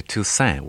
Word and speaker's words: Toussaint: My father used to say Toussaint without an without a T on the end.